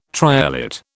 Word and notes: triolet: British English

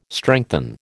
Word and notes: strengthen: American English

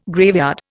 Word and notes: graveyard: Indian

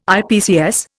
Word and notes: IPCS: Indian

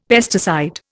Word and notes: pesticide: Indian

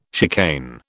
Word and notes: chicane: British English

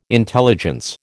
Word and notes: intelligence: American English

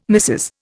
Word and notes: Mrs: Indian

